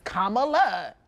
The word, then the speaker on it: Kamala: That's how you say her name. It's Kamala. I mean, to me, it rolls off a little easier.